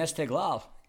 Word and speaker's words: Esteghlal: But Persepolis and Esteghlal, am I saying it right? – Yeah, pretty good, actually.